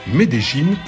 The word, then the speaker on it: Medellín: de Medellín, pardon, Medellín pour le prononcer comme le doyen Willy Dorqueno, « Vámonos ».